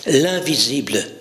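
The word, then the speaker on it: L'1visible: L'invisible, ça s'écrit L', le chiffre 1, suivi de visible. Donc quand vous le prononcez, ça fait l'invisible, mais quand vous le décortiquez, l'un, celui qui est fondamentalement unique, s'est rendu visible.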